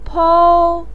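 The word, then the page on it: pou1: tone 1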